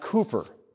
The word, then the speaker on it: Cowper: It looks like his name is Cowper, but it's actually pronounced Cooper.